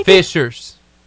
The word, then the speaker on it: fissures: How do you say that again? – Fishers. – Okay, spelled weird. Fishers, vents, and plugs are all associated with which geological feature? – It's also a natural disaster.